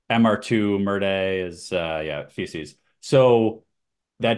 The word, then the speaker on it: MR2: MR2. Some people call it the Mr. 2, right? I've heard of Yeah, both. Right. – So the pronunciation MR2 in French, MR2, merdier, which means excrement or feces.